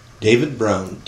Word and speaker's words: David Brown: David Brown, D-A-V-I-D-B-R-O-W-N.